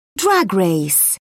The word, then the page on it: drag race: British English